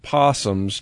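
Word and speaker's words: opossum: Well, fire set to scare off opossums. I can't call them opossums. Fire set to scare off possums destroys man's wooden house. The O is silent.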